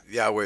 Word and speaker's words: Yahweh: Yeah, Yahweh is the correct pronunciation, yeah.